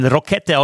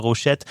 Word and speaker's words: Rochette: Also wir haben uns ja so ein bisschen daran hochgezogen, dass man den Rokette aussprechen könnte wie Rakete, ja, und das passt ja eigentlich ganz gut zum Stürmer, aber er ist natürlich ein französischsprachiger Schweizer, also Theo Rochette und Attilio Biasca sind tatsächlich die einzigen beiden, die ich bei Turnieren noch nicht gesehen habe.